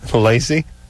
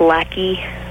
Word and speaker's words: Lacey: If she pronounces it lackey, just spell it lackey.